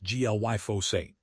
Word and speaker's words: glyphosate: Critics of current protocols, including some wellness-focused researchers, claim that food systems favor reactive drug treatments and inflammatory inputs, such as glyphosate, pronounced glyphosate, which they argue disrupts endocrine function.